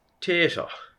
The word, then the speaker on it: Tito: Tato. – Do you know that's just the way they pronounced it? And in the script, it's Tito? – Oh, I didn't know that. – Yeah, T-I-T-O.